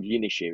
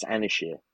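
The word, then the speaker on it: Ynyshir: I look at it and think it's called Yinishir. It's not. It's Anishir because it's spelled Y-M-Y-S-H-I-R.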